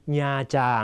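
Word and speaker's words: Nha Trang: ําพิเศษนะครับจะวางอยู่หน้ากริยานะครับมีความหมายว่าก็นะครับเช่นแอมกุ๋งหอกเตียงเวียดเออดายหอกเกษตรศาสตร์นะครับน้องก็เรียนภาษาเวียดนามที่มหาวิทยาลัยเกษตรศาสตร์นะฮะหรืออีกตัวอย่างโบกัวแอมกุ๋งทิกดีญาจาง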